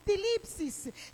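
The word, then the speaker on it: tilipsis (θλῖψις): Y cuando hablamos de la palabra sufrimiento, es una palabra griega que se pronuncia tilipsis, que esto literalmente significa presión.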